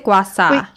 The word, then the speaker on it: gua sha: Gua sha.